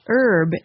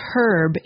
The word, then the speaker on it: herb: For example, the word for a certain kind of plant is pronounced herb in American English and herb in British English.